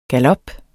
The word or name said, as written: GALOPPERNE